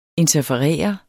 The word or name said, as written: interferere